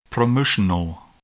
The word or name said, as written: promotional